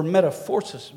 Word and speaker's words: metamorphosis: That word is pronounced in the Greek, metaphoro, or metamorphosis. Metamorphosis is what the way we would say it.